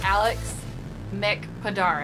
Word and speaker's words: Mic-Podar: Welcome to this episode of Share Your Hotness with your host, and my guest today, Alex Mic-Podar.